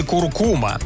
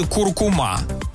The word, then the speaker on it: куркума: Институт русского языка имени Виноградова меняет ударение в слове «куркума». Согласно большому словарю ударений русского языка, ударение на второе «у» теперь становится допустимой старшей нормой. Основной вариант отныне – «куркума». До прошлого года словари приводили только один вариант произношения – «куркума». А вариант «куркума» вовсе нигде не фиксировался.